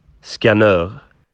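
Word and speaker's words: Skanör: The literal boatloads that they caught would be taken to a place called, and I'm going to struggle with this, Skanur Peninsula? I don't know. We asked to pronounce it, and he gave us this response. – Skanur.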